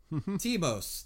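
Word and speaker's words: Thymos: Timos. That's how they pronounce it in the dub.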